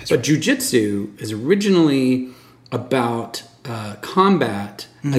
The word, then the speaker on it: jiu-jitsu: And jiu-jitsu... am I saying it correctly? – Mm-hmm. Jiu-jitsu.